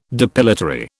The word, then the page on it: depilatory: British English